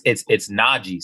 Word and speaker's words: Najee: So Najee, like, you listen to, you probably don't listen to saxophone music, but there's a saxophone player named Najee. And that's how you pronounce my name. It's not hard. It's Najee, you know.